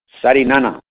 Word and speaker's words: Sarinana: Saul, S-A-U-L. – S-A-U-L. And many a last name also, sir. – Sarinana. – Can you spell it, sir, if you don't mind? – S-A-R-I-N-A-N-A.